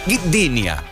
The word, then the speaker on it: Gdynia: Gdynia.